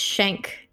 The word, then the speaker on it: Shenk: It's Shenk. – Shenk. – It's very German. Yeah, it's almost like Shenk, like a knife.